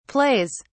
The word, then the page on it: plays: British English